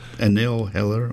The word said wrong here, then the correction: Anil Hira: Anil Hira.